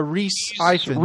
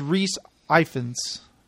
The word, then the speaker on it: Rhys Ifans: How the hell do you pronounce his name? Rhys Ifans or Ifans? – Yeah, Reese Ifans. – Reese Ifans or something. – I think it's Reese Ifans.